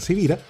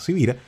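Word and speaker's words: Sivira: Mi nombre es Carlos Javier Civira, y te puedes contactar conmigo en las redes sociales buscando así, Carlos Javier Civira. Civira se escribe con S de Samuel, con B de Victoria.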